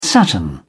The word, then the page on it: saturn: British English